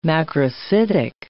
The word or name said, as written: macrocytic